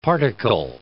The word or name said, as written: particle